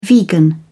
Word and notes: vegan: American English

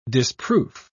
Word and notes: disproof: British English

